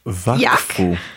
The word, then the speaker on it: Wakfu: Więc dzisiaj mamy z nami serial Wakfu, Wakufu, Wakfu, Wakfu. Wiem, że można mieć trudności z wymową, gdyż jest to serial animowany francuski.